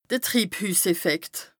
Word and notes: de Triibhüsseffekt: Bas Rhin

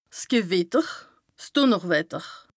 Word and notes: 's Gewìtter, 's Dùnnerwetter: Bas-Rhin; Strasbourg

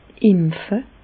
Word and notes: empfe: Bas-Rhin; Reichshoffen

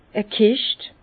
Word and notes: e Kischt: Bas-Rhin; Herrlisheim

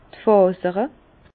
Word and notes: d' Fàsere: Bas Rhin; Herrlisheim

